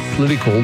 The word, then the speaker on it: political: It says, Tom, you pronounce the word political like Pauline Hanson, as in you forget the O.